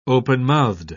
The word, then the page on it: openmouthed: British English